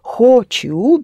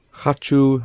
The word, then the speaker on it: хочу: If you pronounce it syllable by syllable, it is хочу, because this is the way it is spelled. But knowing that о is unstressed, you pronounce it хочу, as in я хочу. – As in я хочу, I want.